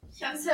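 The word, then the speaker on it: Yaboseo (여보세요): Yaboseo, tá certo. Essa é a pronúncia.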